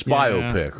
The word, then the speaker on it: biopic: Yeah, so we rhymed it with myopic. – But it's biopic, right.